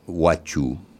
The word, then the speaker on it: huachu: El gaucho, incluso la palabra gaucho, mira, la palabra gaucho que pronunciamos nosotros hoy proviene de huacho con H al principio, que es huachu.